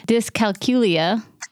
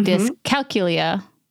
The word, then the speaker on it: Dyscalculia: Quick side note: the the title is Dyscalculia. No, mm-hmm. Dyscalculia. Here we go. Mm-hmm. Dyscalculia. Um, I'm trying to put the accent on the cal and that's throwing me off.